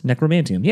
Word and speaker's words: Necromanteum: And then I'm like, is it Necromantium? Yeah, okay. I had to look it up online real quick.